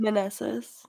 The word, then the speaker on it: Meneses: How do you say his name? Is it Meneses? Am I saying that right? – Meneses, Joey, Red dude.